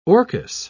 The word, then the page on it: orchis: British English